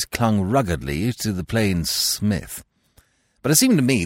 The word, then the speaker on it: Psmith: So I decided to adopt the Smith. The P, I should add for your guidance, is silent, as in Thysis, Psychic, and Ptarmigan.